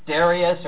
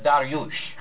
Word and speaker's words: Dariush: And so he told me that the way to pronounce this really was not Darius or Darius, but Dariush.